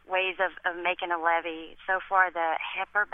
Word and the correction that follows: Hesco: basket, I think is how you pronounce it. – Hesco basket. What is that?